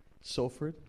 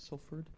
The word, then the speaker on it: Salford: Salford? Salfred? Yeah. You guys say it a little differently. I read it and I'm like, is that what they're saying? – Pronounced like an O.